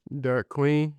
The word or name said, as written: derik QUEEN